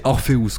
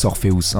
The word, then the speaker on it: Orpheus: Du coup, je l'ai mis euh, en version espagnole ou anglaise, mais en tout cas, je voulais pas que ça fasse Ulysse Orphée, j'ai préféré Ulysse Orpheus. Donc, c'est Orpheus que ça se prononce. Voilà, Ulysse Orpheus, un peu comme un nom de sorcier.